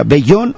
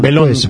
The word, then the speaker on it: Belón: Oye, ¿cómo se pronuncia? ¿Bellón o Belón? – Belón, pues, profe. – Belón. Es que como tiene doble L, ¿no? – ¿Sí?